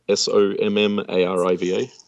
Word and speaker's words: Sommariva: S O M M A R I V A.